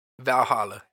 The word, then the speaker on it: Valhalla: For Raw, they picked Eric Ivar and, how do you say that, Valhalla? – Valhalla, yes.